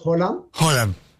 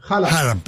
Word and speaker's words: Haaland: En Noruega decimos Haaland. Pero acá, Haaland.